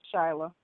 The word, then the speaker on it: Shayla: Is it Shayla or Shyla? I think it's Shayla. – Shyla. – All right. – Shyla.